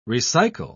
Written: recycle

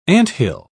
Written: anthill

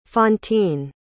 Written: fantine

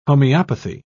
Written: homeopathy

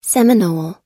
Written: seminole

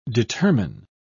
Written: determine

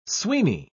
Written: sweeny

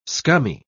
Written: scummy

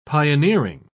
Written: pioneering

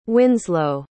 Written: winslow

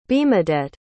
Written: bemadette

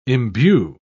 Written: imbue

